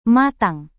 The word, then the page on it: matang: Indonesian